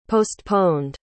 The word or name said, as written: postponed